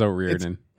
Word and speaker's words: O'Riordan: O'Rourdan. – Or whatever. – I think I'm saying it wrong still. – Yeah, I think it was O'Riordan. – O'Riordan, yeah, there you go. It's Dolores O'Riordan.